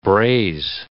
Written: braze

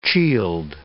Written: chield

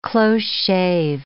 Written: close shave

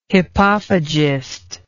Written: hippophagist

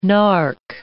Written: narc

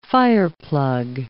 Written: fireplug

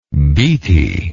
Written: beatty

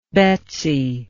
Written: Betsy

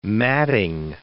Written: matting

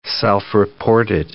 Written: self-reported

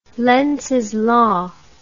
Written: lenzs law

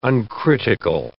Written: uncritical

